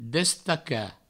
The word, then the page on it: destacar: Provençal; destaca